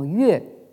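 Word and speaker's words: yue (乐): qui se prononce actuellement yue et qui en réalité en fait a une double prononciation euh, c'est-à-dire donc euh, quand on le prononce ça veut dire donc la, musique, hein.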